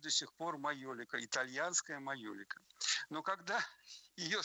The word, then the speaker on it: майолика: И в итальянской транскрипции это звучит как майолика.